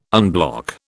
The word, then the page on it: unblock: British English